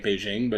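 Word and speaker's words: Bijen: It's pronounced like Beijing, but it's B-I-J-E-N.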